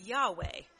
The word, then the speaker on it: Yahweh: The Hebrew word for the Lord here is YHWH, which is pronounced Yahweh.